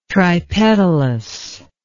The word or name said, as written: tripetalous